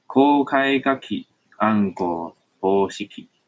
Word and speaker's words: 公開鍵暗号方式: 念法叫做“ 公开卡基安国波西基”，公开卡基安国波西基。